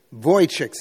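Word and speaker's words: Vujicic: Nick Wojcicks is how you pronounce it.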